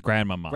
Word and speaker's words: grandmama: it's grandmama. – Grandmama. – Pronounced. – Yeah, there's you got to go up on the second ma.